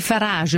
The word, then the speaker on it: Farage: dicevano oggi Grillo e Farage, è vero che si pronuncia questo del leader inglese del partito populista Farage alla francese? – Sì, Farage, ecco.